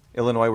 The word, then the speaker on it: Illinois: Illinois, where the S is silent.